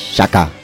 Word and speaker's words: Xhaka: Granit, como suena. Granit. Y el apellido es Xhaka. Xhaka.